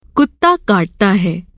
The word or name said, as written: कुत्ता